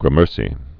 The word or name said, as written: gramercy